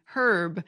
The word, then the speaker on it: herb: So the modern British H pronunciation, herb, appears